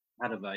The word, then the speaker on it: Padova: Padova? – Padova, yeah.